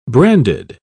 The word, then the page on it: brandied: British English